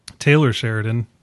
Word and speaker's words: Taylor Sheridan: Once again, this movie is written by Tyler Sheridan. Taylor Sheridan, talking about mispronouncing people with T's in their name.